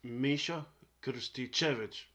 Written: Miso Krsticevic

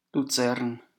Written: Luzern

